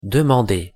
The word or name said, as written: demander